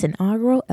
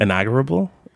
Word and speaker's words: inaugural: We're glad you tuned in to this inaugural episode of season 5.